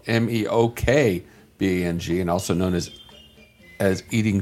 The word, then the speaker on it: mukbang: I'm ready for some mukbang. Don't you have to say it like that? Mukbang. – I think that's the pronunciation. Yeah, mukbang.